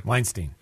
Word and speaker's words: Weinstein: Weinstein.